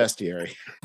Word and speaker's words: bestiary: Oh, no, that's definitely bestiary. There's no A.